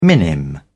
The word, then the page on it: minim: British English